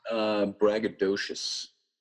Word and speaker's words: braggadocious: Braggacious. How do you pronounce that well? – Braggadocious. – Braggadocious.